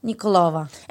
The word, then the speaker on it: Nikolova: Elena Nikolova.